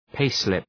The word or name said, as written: payslip